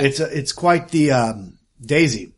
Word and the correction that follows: doozy: Doozy. Doozy. – Oh yeah, it's doozy. That's how you say it.